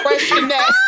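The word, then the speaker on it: Freixenet: Girl, first of all, that's pronounced fresh in that.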